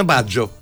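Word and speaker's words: Baggio: Το ξέρεις ότι στην ουσία δεν προφέρει το γιώτα. – Εντάξει. – Αλλά αν το ακούσω από Ιταλό. – Στον Άγγελο προφέρεται. – Στον Άγγελο, ναι. – Ναι, Μπάτζιο είναι ο άνθρωπος. Στο Ρομπέρτο είναι Μπάτζο. – Μπάτζο. – Μπάτζο. – Ναι, ναι. Το τρώνει το γιώτα.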